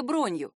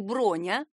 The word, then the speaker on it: бронь: Официальное закрепление за кем-то билетов называется бронью. Именительный падеж — бронь или броня, именно с ударением на «о».